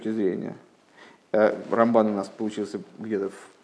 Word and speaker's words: Рамбан: Рамбана а, для того, чтобы не путать э, Рамбана и Рамбама, э, мудрецы специально решили, что Рамбана будут говорить с ударением на второй слог, а Рамбама на первый.